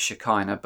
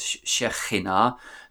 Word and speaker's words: Shekhinah: Most people pronounce that as Shekinah, but in Hebrew it's pronounced as Shekhinah, the KH being a ch.